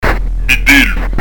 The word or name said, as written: vitellu